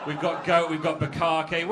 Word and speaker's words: bukkake: Buckcake. – What was that? – Buckcake. – It's pronounced bukkake, but we will...